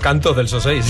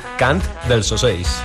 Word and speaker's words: Cant dels Ocells: Además lo tenías bien escrito, Cant dels Ocells, y has dicho Canto dels Ocells.